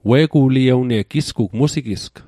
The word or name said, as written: musigisg